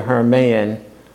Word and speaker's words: Hermann: First of all, I'd like to say on the back board, my name is spelled H-E-R-M-I-N. It's actually Hermann, but that's okay.